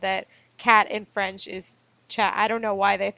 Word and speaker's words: chat: Yeah, it's pronounced chat, Chat, so it looks like chat.